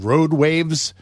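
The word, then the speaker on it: RoadWayve: There's this new device called RoadWaves, mm-hmm. right? And it's spelled W-A-Y-V-E, RoadWave, rather, right?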